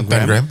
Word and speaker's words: Pangram: Pangram, toch? – Ja. – Als je het goed wil uitspreken. – Ja, Pangram, ja.